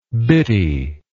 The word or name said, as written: bittiness